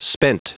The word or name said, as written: spent